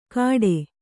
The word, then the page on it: ಕಟೆ: Kannada